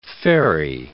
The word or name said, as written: ferry